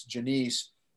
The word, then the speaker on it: Jonice: It's spelled J-O-N-I-C-E, but it's Janice.